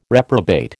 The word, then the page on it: reprobate: American English